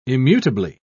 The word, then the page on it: immutably: British English